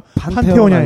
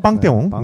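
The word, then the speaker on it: Panthéon: 판테온이 아니라 빵떼온이 네, 네, 네.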